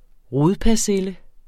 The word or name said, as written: rodpersille